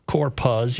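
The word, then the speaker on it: Corpuz: Korpuz, yeah.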